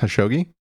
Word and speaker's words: Khashoggi: And then Jamal, I think his name is pronounced Khashoggi. So he was he's a journalist, essentially.